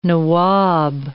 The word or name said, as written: nawab